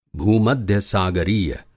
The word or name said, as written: भूमध्यसागरीय